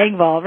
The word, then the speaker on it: Engvall: Engvall. Did I say that right? You Wrong? did. Engvall. Right.